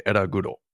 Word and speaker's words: Ellaguro: I always pronounce it in Japanese in my head. – That's fine. – I say Eraguro, how I pronounce it, in case you needed to know that.